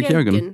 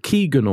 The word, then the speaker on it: Keoghan: Is that how you pronounce Barry's name? – Barry Keoghan. Keoghan. Yeah. I always thought it was something like Keegan or Ke- Keegan.